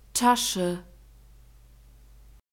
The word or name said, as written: tasche